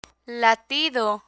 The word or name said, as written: latido